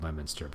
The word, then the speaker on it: Leominster: So like one that came up was, there's a town called Lemonster, and I even know that this one is called Lemonster, but I forgot. And so I called it Leominster, I think, and I was pretty quickly corrected.